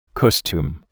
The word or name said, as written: costume